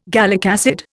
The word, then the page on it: gallic acid: Indian